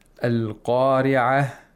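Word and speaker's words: Al-Qari'ah (القارعة): Qari'ah at the end has a ta marbuta, which means that it is only pronounced like a ta in continuation, but not when you stop. So if you stop, it turns into a ha. Al-Qari'ah.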